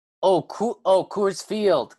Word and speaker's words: Coors Field: Coors Field. Coors Field. Gotcha. – Coors Field. Rhymes with Doors Field.